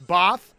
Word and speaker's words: Bothroyd: If you saw the pronunciation guide, which I did from Wake Forest, B-A-H-T-H, would you say that's Both? – Both, yeah.